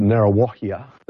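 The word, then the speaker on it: Ngāruawāhia: They did come and play in New Zealand, and someone in this concert, Narawahia, don't ask me to spell that for you, but that's how you pronounce it,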